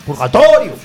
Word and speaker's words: Purgatorio: llamado Purgatorio, con acento en la primera O. Ojo, Purgatorio.